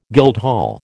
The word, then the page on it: guildhall: American English